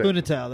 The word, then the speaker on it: Funitel: How do you say Funitel, that's right.